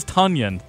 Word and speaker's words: Tonyan: Yeah, I got to make sure I pronounce that right, because it's easy to say Tonyan, Bears. He is Tonyan. Thank you, texters.